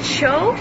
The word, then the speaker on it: choux: A cho pastry shell is Beard Papa's cream puffs consists of two-layer shell. The inner shell is a cho pastry. Am I pronouncing that correctly? – C-H-O-U-X.